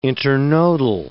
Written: internodal

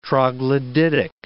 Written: troglodytic

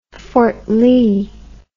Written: Fort Lee

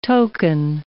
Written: token

